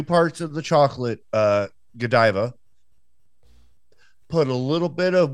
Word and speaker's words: Godiva: Is it really Godiva? – Yeah, it's Godiva. Lady Godiva.